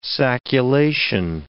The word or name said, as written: sacculation